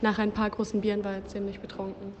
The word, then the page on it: betrunken: German